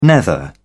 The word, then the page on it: nether: British English